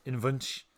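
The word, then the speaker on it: Invunche: How do you say it? – Invuce. Invunch. The Invunch.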